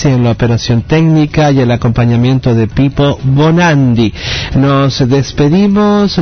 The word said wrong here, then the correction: Bonaudi: ¿Nos está bien dicho Bonandi? No, lo dije mal. Ay, su compañero me dijo mal el nombre, ¿ves? Para la próxima lo pongo bien. Ah, ¿está bien Bonandi? Sí. Bonaudi. Ah, ahí está. A ver, Pipo Bonaudi. Dije Bonandi, Bonaudi, muy bien.